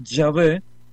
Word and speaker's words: Yahvé: Pero luego se dieron cuenta, no, la mejor pronunciación de esto es Yahvé,